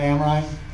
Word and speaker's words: Amrhein: My name's John Amrine, spelling A-M-R-H-E-I-N.